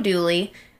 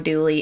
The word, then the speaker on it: Dula: Tom Dooley. The real Tom Dooley is spelled D-U-L-A. Turns out it's actually pronounced Dooley.